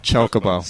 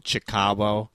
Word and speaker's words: Chocobo: Kokobo? – Chocobo.